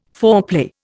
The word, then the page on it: foreplay: Indian